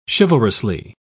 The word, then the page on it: chivalrously: British English